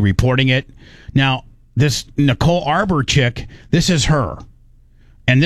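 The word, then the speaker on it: Arbour: Me getting into the Nicole Amber deal... Arbor deal. I don't know how you say it. It's Nicole Arbor. Arbor.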